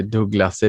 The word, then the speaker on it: Douglas: Nu kan någon också fråga sig, Douglas, är det rätt uttalat? Det är faktiskt det.